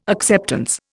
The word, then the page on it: acceptance: Indian